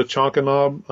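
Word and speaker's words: Chankanaab: But we ended up going to Chonkanob. I think that's how you pronounce it. – Yeah, that's it.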